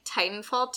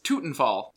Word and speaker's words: Titanfall: We are reviewing Titanfall 2.